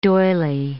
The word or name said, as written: doily